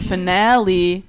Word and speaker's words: finale: This is kind of visual, but finally is pronounced final E, but finale is pronounced finale. See, it's switched, if you didn't understand.